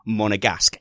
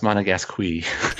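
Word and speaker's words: Monégasque: Let's start developing towards the young Monegasque. Yes, I said it. Monegasque. First time. Broke the seal. – I believe it's pronounced Monegasque.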